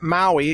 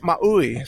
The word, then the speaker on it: Maui: And it's not Maui, it's Maui. So at least that's my understanding.